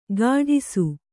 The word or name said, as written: ಕಾಡಿಸು